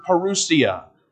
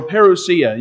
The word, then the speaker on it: parousia: It is the Greek word parousia, or parousia. You can pronounce it a couple of different ways.